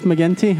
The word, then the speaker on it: McGinty: uh, and we have uh, Keith, Keith McGinty, Hard G, Good the evening.